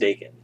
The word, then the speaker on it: Daken: Draken? – I think it's pronounced Daken. – Daken. I've only ever read it.